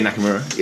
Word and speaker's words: Nakamura: Is it Nakamura? – Oh, yeah, Nakamura, yeah.